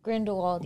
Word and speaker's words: Grindelwald: How do you say his name? Grindelwald? – Grindelwald, yeah. Grindelwald. It's just him Johnny and Grindelwald Depp.